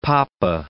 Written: poppa

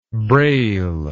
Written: brale

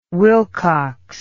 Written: Wilcox

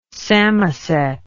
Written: Samoset

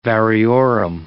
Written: variorum

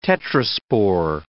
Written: tetraspore

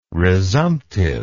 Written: resumptive

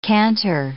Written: canter

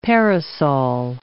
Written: parasol

